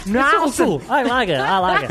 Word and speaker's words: Knoesen: Met a silent K, is a Knoesen. – Knoesen! I like it.